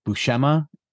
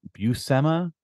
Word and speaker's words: Buscema: Recently, you said Buscema, pronouncing the C, and it should be Buscema.